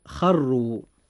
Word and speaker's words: خروا: نجد أن كلمة خروا الراء فيها مضمومة، فيجب أن تفخم أيضا عند النطق هكذا: خروا.